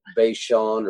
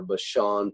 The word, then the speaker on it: Bashan: Now, we don't have time to do an exhaustive study of that word Bashan or Bashan. People pronounce it in different ways.